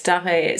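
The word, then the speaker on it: Darré: And so who's this other figure who I've got my notes here, who also seems to play a significant role, forgive me if I say it wrong, Richard Walter Dare? – Yeah, right, Dare.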